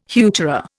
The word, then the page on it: heuchera: Indian